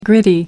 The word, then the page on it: gritty: American English